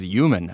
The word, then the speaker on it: human: This listener is always writing me and criticizing me for saying human instead of human, for using a Y sound instead of an H sound at the beginning of the word.